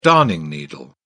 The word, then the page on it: darning needle: British English